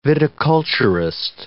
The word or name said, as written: viticulturist